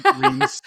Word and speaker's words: Reims: Reims? I think it's, is that how you say it? Reims? – Yeah, Reims. Reims, yeah. – Reims. R-H-E-I-M-S is how you spell it.